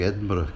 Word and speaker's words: Edinburgh: It's nice to hear you pronouncing Edinburgh correctly. – I had a teacher in middle school who was from Edinburgh, so I learned how to say it even with a bit of a brogue as well.